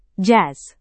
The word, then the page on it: jazz: Indonesian